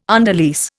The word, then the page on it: underlease: Indian